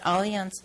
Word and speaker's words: Allianz: It's pronounced Allianz Insurance, and they're part of Fireman's Fund.